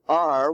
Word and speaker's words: are: We give them also the irregular words, or R, which is in the bear, care, dare family, A-R-E, but it's not pronounced air, it's pronounced R. Maybe at one time it was pronounced air, it might have, but it's now pronounced R. And H-A-V-E is not pronounced have, it's pronounced have.